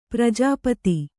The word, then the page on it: ಪ್ರಜಾಪತಿ: prajāpati